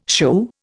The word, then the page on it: shoo: Indian